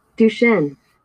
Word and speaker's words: Duchenne: Duchenne? Cannot be saying that right either. I'll make sure you get some of these right. I'm getting a lot of the names wrong. – Duchenne.